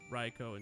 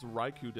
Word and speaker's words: Raikou: Also, we pronounce Raikou differently. I always known it as Raikou. – Raikou. – Raikou? Really? – K-O-U in Japanese is Ko.